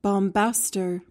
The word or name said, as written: bombaster